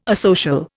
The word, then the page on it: asocial: Indian